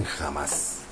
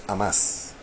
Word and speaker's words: Hamas: No sé por qué aquí en México le dicen jamás, pero es Hamas. Correctamente pronunciado. Aquí, allá sí eh, tiene sonido propio la, H.